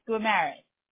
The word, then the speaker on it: Gumeris: People usually get it right. It's Gumeris. – Gumeris. Okay. Yeah. Just like it sounds. Okay. – Yeah. – Or just like it's spelled.